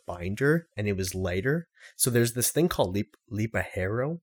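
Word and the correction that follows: ligero: or Lipero. I forget how to say it. No, it's Lijero, okay?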